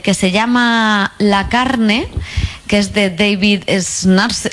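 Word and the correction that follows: Szalay: Ahora pronunciaré bien su nombre. David Salai.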